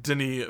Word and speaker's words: Denis: But I have faith in Denis Villeneuve. I think I say that right. I, it's so difficult. Um, I just know you don't say the S.